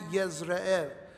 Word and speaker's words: Yezreel: There's no J in the Hebrew, and so it's pronounced like a Y, Yezreel.